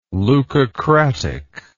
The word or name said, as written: leucocratic